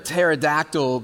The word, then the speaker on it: pterodactyl: Why can't you hear a pterodactyl going to the bathroom? It's because the P is silent.